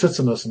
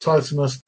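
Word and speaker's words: tetanus: But titanus is pronounced titanus and not titanus because there is a double N.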